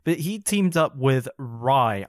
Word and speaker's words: Rhye: It's like rye bread. Um, yeah. it's not spelled like that, though. It's R H Y E.